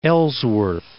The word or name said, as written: Ellsworth Land